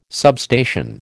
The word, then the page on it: substation: American English